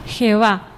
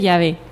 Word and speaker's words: Yahvé: Y si tomáis un diccionario bíblico de categoría, como por ejemplo el preparado por el padre Ausejo en editorial Herder, dice Jehová, falsa pronunciación del nombre Yahvé.